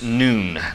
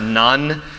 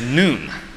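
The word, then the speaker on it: Nun: And the letter that marks this section is pronounced noon, not none. That is something different that some of you grew up with who through rulers across your knuckles. This word is noon.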